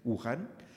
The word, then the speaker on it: Wuhan: Wuhan se llama, ¿no? Creo que sí se pronuncia, Wuhan, donde está el centro de la aparición del coronavirus, de, de esa enfermedad y escribió una carta a él.